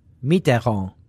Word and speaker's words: Mitterrand: which of course is to go kill Micharron. – Yeah, that's not how you say it, but what did you say? – Mitterrand. – Micharron. – It's funny to hear you try to say it.